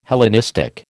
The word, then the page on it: Hellenistic: American English